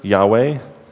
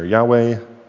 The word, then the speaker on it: Yahweh: So the Lord came down in the cloud and stood there and proclaimed his name, the Lord, or in Hebrew, Yahweh. That's how we think it's pronounced. But there's vowels missing, so you can never be quite sure. Yahweh.